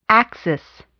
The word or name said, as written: axis